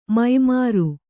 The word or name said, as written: ಮೈಮರೆ